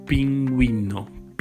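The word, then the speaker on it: pingüino: But if the letter U has two dots over itself, it's the diaresis, we have to pronounce it. Like in the word for penguin in Spanish, the G is following by the U with the diaresis with two dots on over it, and we have to pronounce it. So it'd be pinguino, pinguino. It's not pinguino, but pinguino. Okay, thank you for listening, and we will see each other in the next episode.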